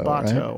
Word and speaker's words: Sabato: How do you say it? Because I know there's Larry Sabato. – Yeah, uh, Sabato, uh, Sabato right? I believe. It's Antonio so Sabato.